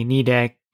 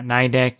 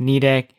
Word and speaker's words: Nidec: So I filmed a couple of videos on the Nidec VS motor, and I am pronouncing the name correctly, Nidec, not Nidec, even though it sounds like Nidec if you read it, but it's a Japanese company. And it's pronounced Nidec.